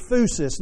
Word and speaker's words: phusis: The Greek word that's used there is phusis. Let me just spell it for you. It's P-H-U-S-I-S, phusis.